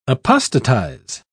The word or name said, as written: apostatize